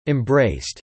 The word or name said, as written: embraced